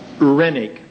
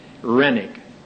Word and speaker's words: Renwick: Rennick, R-E-N-W-I-C-K. The W is silent. Rennick.